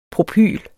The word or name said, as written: Propyl